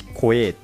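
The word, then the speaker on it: CoA: COC とちっちゃい O を書いてコエーって読む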